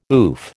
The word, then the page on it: oof: American English